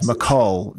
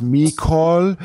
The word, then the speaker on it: McCall: And the other day, the gas company sent my bill with MEEKOL, I think it was Mecol, which kind of, you know, rhyming with fecal, so you know, not so great.